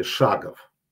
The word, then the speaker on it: шагов: Ударение на первом слове «шагов». Ну, что не шаги, а шаги.